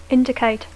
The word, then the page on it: indicate: English